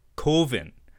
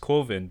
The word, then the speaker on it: Coven: It does include his short film Coven, which is of course pronounced Coven because coven would be like oven, man.